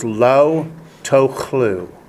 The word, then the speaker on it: lo tochlu: In Hebrew, that is lo tochlu. Spell it L-O space T-O-C-H-L-U. Lo tochlu.